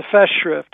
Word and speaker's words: Festschrift: So tell me a little bit about the, I'm going to say this wrong, Festschrift, is that right? – The Festschrift, it's a German word.